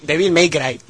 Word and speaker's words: Devil May Cry: ¿Podrá ser uno de ellos Devil May Cry o Tekken? No estaría nada mal. – May Cry. – Devil May Cry, ¿no? A ver. – Devil May Cry.